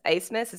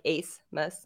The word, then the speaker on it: Abmas: Yeah, it's because it's spelled A-B-M-A-S. So I would have this whole time been like, oh yeah, Texas got a transfer from Max Abmus. – Right. – Glad. I'm very glad that you just said his name. That's the first time I've heard somebody say it. – So the B and everything else is silent in that name.